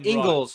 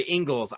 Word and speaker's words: Ingles: I think I'm expecting like Joe Ingles, like the role players for Utah. – Ingles. He's an Australian. Say his name. – Ingles. – Right. – Sorry, Ingles.